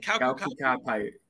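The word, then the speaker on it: Kaukokaipuu: Kaukekepu, right? Isn't it Kaukekepu? – Yes, it is.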